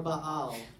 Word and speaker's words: Baal: I can say Baal! Or Baal is the correct pronunciation.